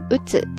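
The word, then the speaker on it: 鬱: 抑郁症在日语当中呢，叫做うつ病、うつ病、うつ病ですね。汉字写作郁，忧郁的郁，再加上疾病的病。うつ病ですね。其中前半部分的郁，忧郁的郁，读作うつですね。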